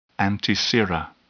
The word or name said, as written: antisera